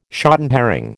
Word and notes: shotten herring: American English

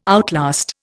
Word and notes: outlast: Indian

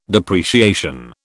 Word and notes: depreciation: British English

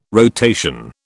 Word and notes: rotation: British English

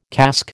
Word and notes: casque: American English

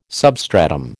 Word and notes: substratum: American English

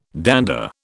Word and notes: dander: British English

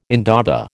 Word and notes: indaba: American English

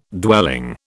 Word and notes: dwelling: British English

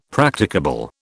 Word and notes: Practicable: British English

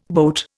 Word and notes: boat: Indian

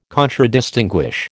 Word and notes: Contradistinguish: American English

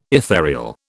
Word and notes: ethereal: British English

